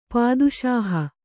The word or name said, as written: ಪಾದುಶಾಹ